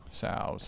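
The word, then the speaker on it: Tso's: But I thought it was So's. – I think the T is silent. – Yeah. So's.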